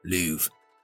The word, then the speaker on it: Louvre: That's how you pronounce it, Cummins. Louvre.